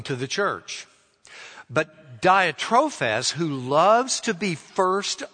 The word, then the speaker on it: Diotrephes: A man named Diotrephes. That's the best way to pronounce it.